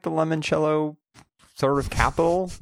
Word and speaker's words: limoncello: It is Limoncello. That's how you pronounce it.